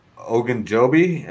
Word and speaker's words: Ogunjobi: Defense, with the ads they had, Awuzie, Ogunjobi. Am I bad? – Yeah, you got it.